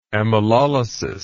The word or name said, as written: amylolysis